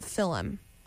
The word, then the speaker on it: Fulham: It's called The Goose, and it looks like Fulham, but everyone says they pronounce it Philem.